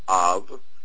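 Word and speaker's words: Av (אב): You have a word that is spelled Aleph Bet. It means, it's said Av, and it means father.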